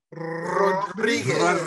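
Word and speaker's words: Rodríguez: Hey, William, you have to pronounce it properly. Julio Rodriguez.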